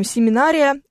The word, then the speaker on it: семинария: На всякий случай вам вдруг сообщу, что в слове «семинария» ударение падает на букву «А».